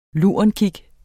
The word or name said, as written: lurenkig